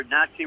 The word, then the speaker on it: Nazi: And remember, Nazi rhymes with Yahtzee.